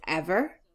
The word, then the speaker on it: Emer: Like, for example, in Cú Chulainn, in the tale of Cú Chulainn, Cú Chulainn's wife has a name which we now would usually pronounce Emer, but was actually probably at the time pronounced Ever.